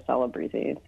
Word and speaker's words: Celebrezze: but they changed all the I's to E's, and then it was kind of weirdly Americanized to Celebreeze. So that's how I pronounce it.